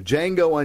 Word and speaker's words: Django: Is it Django or Django? – The D is silent. – Okay, so Django Unchained from 2012.